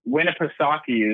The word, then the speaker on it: Winnipesaukee: Winnipesaukee. – Winnipesaukee is the big lake up there.